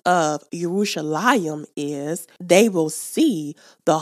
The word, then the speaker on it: Yerushalayim: Now, in Hebrew, the word Jerusalem is pronounced Yerushalayim.